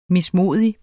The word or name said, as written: mismodig